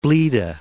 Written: Blida

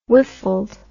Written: whiffled

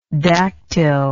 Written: daktyl

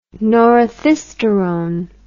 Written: norethisterone

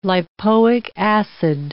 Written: lipoic acid

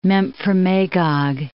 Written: Memphremagog, Lake

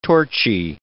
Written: torchy

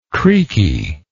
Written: creaky

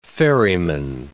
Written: ferryman

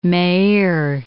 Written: Meir

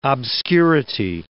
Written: obscurity

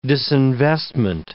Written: disinvestment

